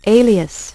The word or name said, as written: Alias